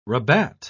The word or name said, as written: rabat